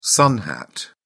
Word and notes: sun hat: British English

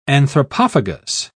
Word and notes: anthropophagus: British English

